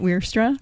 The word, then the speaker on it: Wierstra: I'm Annette Weirstra.